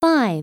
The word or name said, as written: 5